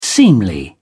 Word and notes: seemly: British English

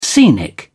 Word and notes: scenic: British English

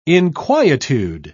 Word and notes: inquietude: British English

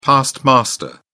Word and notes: past master: British English

